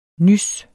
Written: nys

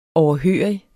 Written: overhørig

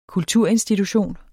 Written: kulturinstitution

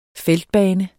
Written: feltbane

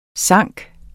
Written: synke

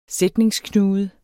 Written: sætningsknude

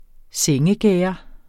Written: sengegærde

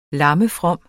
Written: lammefrom

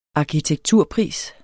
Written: arkitekturpris

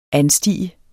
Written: anstige

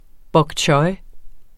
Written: pak

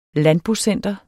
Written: landbocenter

